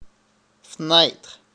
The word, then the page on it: fenêtre: France; [yn fø.nɛtʁ̥]